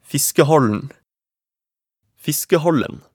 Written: Fiskehallen

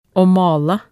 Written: å male

